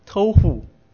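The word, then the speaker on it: tohu (תהו): 混沌这个字在希伯来文里面念作 tohu，所以混沌就是豆腐，豆腐就是混沌，是很好记吧。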